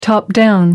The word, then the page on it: top-down: American English